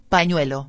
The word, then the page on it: panuelo: espanhol